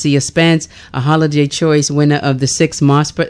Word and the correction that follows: Mopser: How do you say MOSPER? – MOPSER. – MOPSER. See, I think you said MOPSER.